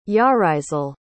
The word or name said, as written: yarisel